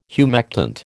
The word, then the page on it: humectant: American English